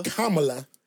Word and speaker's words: Kamala: Is it Kamala? – Kamala Harris, HBCU grad.